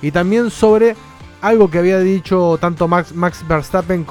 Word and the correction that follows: Verstappen: como Verstappen, para que se ponga bien, para que lo pronuncie de la manera correcta, porque Dani se enoja.